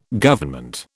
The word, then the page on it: Government: British English